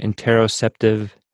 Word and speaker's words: interoceptive: Can you go a little bit more into detail about see if I'm saying it right, interoceptive sensations. Am I saying that right? – Yeah. Yeah, okay. You are.